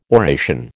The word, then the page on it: oration: American English